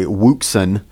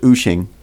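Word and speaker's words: Wouxun: It looks like, by the way, Wuxin, but I believe it's pronounced Uxin.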